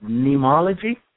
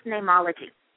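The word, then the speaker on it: neimology: Is it nemology? – It's spelled from the Latin root of the word for name, which is N-E-I-M-ology, mm-hmm. but it's pronounced nemology.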